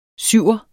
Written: syver

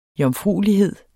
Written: jomfruelighed

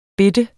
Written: bitte

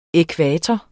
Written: ækvator

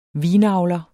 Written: vinavler

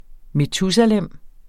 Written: metusalem

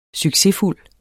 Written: succesfuld

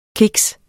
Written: kiks

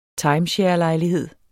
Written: timesharelejlighed

